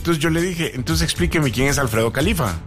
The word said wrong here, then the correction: Jalife: Alfredo Jalif. – Ah, Jalif, eso. – Se escribe Jalife. – Jalife. – Jalife. – Sí, es Alfredo Jalif Ramírez.